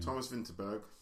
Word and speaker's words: Vinterberg: I think you need to give it a little bit more accent to get on the Vinterberg train. – Thomas Vinterberg.